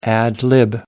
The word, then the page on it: ad-lib: American English